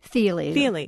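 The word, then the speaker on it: Thile: You mentioned the Chris Thiele. Am I saying his name right? – Thiele. Yeah.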